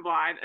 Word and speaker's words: Blind: I've been living 22 years with Morgan Blind.